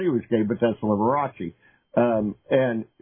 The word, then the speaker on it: Liberace: Liberace? – Liberace.